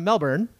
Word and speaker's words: Melbourne: I said the name right, and how do you pronounce where you live? – Melbourne.